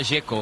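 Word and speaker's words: Džeko: Porque DZ, ¿cómo se pronuncia? – Dzeko. Bruno dice que es Dzeko. – Como tirando a J, Dzeko o algo así.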